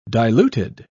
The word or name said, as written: diluted